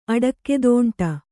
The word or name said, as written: ಅಡಕ್ಕೆದೋಂಟ